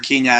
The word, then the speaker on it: Kenya: I would love to get involved with whatever work you're going to do with Kenya Next, or Kenya is how the proper way to say it.